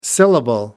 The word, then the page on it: syllable: British English